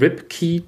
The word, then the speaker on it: Ripke: Ja, es gibt so eine, eine Rubrik, die die beiden haben, die nennt sich der Rip Key to Success, äh, angelehnt an Pauls Nachnamen Ripke, äh, der in Amerika Ripkey ausgesprochen wird.